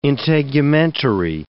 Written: integumentary

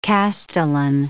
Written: castellan

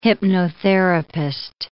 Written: hypnotherapist

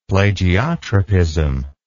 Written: plagiotropism

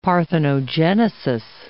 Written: parthenogenesis

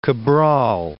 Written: Cabral

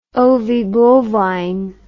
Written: ovibovine

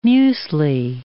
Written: muesli

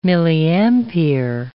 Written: milliampere

